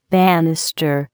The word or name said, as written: banister